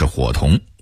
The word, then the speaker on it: 胡同: 在江南一带，胡同的发音就是火同，而且也是小巷的意思。